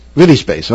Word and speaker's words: VIDI Space: It is currently available on Amazon Prime and VIDI Space. How do you pronounce it? Am I saying that right? VIDI Space? – Space. Viddy Space. – okay.